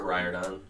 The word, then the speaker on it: Riordan: Rick Riordan. – Riordan.